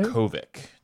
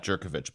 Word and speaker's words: Jurkovec: Jurkovec, not Jurkovec, but Jurkovec.